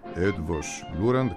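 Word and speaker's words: Eötvös Loránd: Έντβος Λούραντ, κάπως έτσι προφέρεται. Καλημέρα σας, κύριε Φωκά, στη Βουδαπέστη από την Αθήνα. – Καλημέρα σας. Επιβεβαιώνω ότι η προφορά σας είναι τέλεια όσον αφορά τώρα το όνομα του Πανεπιστημίου.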